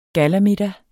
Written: gallamiddag

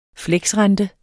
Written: fleksrente

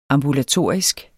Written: AMBULATORISKE

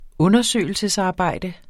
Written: undersøgelsesarbejde

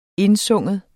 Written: indsynge

